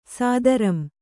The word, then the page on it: ಸ್ಥಿರಂ: sthiram